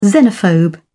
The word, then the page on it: xenophobe: British English